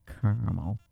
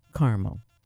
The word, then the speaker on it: caramel: Caramel, Carmel. How do you say it? Growing up in the Midwest, we always said Carmel. I don't know. I think it both are accepted.